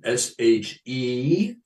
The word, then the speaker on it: Shepherd Siegel: But it is spelled like the occupation, Shepherd Siegel.